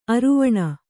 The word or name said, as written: ಅರೆವೆಣ್ಣು